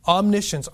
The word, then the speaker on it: omniscience: Omniscience, omni means all, and the word like S-C-I-N-T, I don't even know how to pronounce that, shins, omniscience. Is knowing.